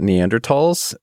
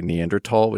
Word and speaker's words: Neanderthal: Dana and I are going to say Neanderthal, which is the correct pronunciation. – I tried to blur it just now. – And you're saying the sort of debased, Americanized version, Neanderthal, just so we're clear on that.